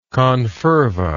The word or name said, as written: confervoid